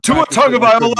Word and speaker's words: Tagovailoa: Tagovailoa. Sorry, I can't. I tried to pronounce the first name. – Tua Tagovailoa. I just take off the two and say Tagovailoa. There you go.